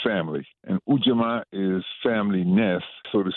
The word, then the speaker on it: Ujamaa: Ujamaa, that's the proper pronunciation because the double A at the end of words in Kiswahili means that that's where the accent goes.